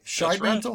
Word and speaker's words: Shidemantle: Shidemantle? – Right.